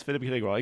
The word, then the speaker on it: Philippe Grégoire: Yes, Philippe Grégoire, exactly.